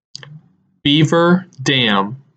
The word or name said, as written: Beaver Dam